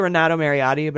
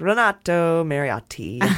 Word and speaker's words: Renato Mariotti: Yeah, I could say Renato Mariotti, but I'm like, Renato Mariotti. Hit those T's hard.